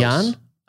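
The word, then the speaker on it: Jahn: How do you spell that last name? – J-A-H-N. I think I'm close. – Jan? Okay.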